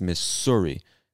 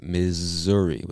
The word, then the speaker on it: Missouri: So I know if you're reading this, you see the two S's and you might think it's Missouri, but we pronounce it Missouri with like a Z sound.